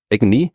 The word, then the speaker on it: AGNI: Aber wenn du ein I hinten dran hängst, dann kannst du es wie ein Wort aussprechen, nämlich als Agony oder Agony, was ja auch ein englisches Wort ist und wie übersetzt man Agony am besten auf Qual.